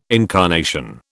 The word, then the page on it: incarnation: British English